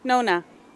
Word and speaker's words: Nona: C-O-T-Y. – And where are you from? – Rhode Island. – What's your name? – Nona. – Nona, can you spell that? – N-O-N-A.